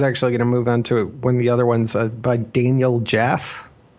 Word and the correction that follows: Jaffe: I think that's how it's pronounced. – Daniel Jaffe. – Daniel Jaffe. Okay.